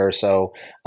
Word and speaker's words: Urso: I'm at Coach Mike Urso.